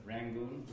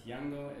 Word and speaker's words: Yangon: Instead of Rangoon, we are calling it Yangon, because Yangon is a correct pronunciation of the word. Rangoon is an anglicized pronunciation.